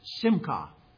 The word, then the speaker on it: simcha: That word, the word that is most often used, is pronounced simcha.